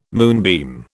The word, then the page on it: moonbeam: British English